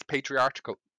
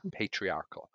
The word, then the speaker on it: patriarchal: And again, I think it's because it's patriarchal. I keep saying that wrong. – Patriarchy. – Thank you. Can you say it again? – Patriarchal. – I think that's what it is.